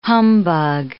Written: humbug

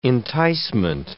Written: enticement

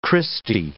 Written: Christie

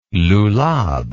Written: lulab

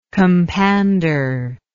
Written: compander

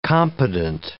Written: competent

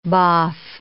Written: boff

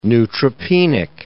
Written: neutropenic

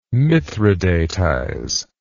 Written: mithridatize

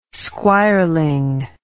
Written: squireling

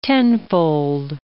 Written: tenfold

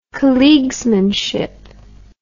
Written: colleaguesmanship

